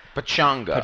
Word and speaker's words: Pechanga: I don't know if I'm saying that right. – Pechanga. – Pechanga. Yeah, it's about 90 Pechanga.